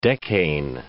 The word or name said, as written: decane